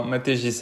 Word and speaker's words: Maté: Déjà, est-ce que je n'ai pas écorché ton nom Non, non, maté c'est bien. Comme ça se prononce.